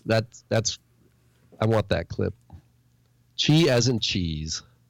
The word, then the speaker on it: chi: Chi is pronounced chi, as in cheese.